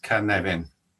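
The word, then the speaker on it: Cynefin: First of all, Canavin is a Welsh word, so it's pronounced Canavin, yeah?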